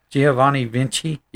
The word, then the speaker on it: Giovanni Vinci: Giovanni Vinci? – Yes.